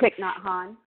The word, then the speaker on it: Thich Nhat Hanh: Thich Nhat Hanh. I think I screwed it up. – Thich Nhat Hanh.